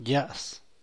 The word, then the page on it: ya's: San Mateo Macuilxochitl Zapotec